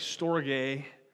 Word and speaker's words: storge: There's also less known, it's pronounced like storge, but add like some Greek flair in there, and then it would be right.